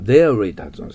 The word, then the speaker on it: dairy: これはデイリーと発音するんです。デイリー。 R ですからね。